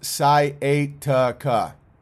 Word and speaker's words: sciatica: And Skyacia is pronounced sciatica.